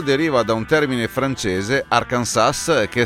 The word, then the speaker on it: Arkansas: decretando che la pronuncia ufficiale Arkansas alla S finale muta.